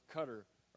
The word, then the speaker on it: Qatar: Then I received today in the email, a letter from missionaries in Qatar, which is pronounced Cutter around here if you're American.